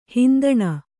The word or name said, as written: ಹಿಂದಣ